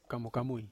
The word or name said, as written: kamukamui